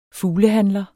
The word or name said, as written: fuglehandler